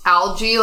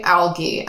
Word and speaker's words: Algie: Okay, British people pronounce Algie, like sea algae, Algie.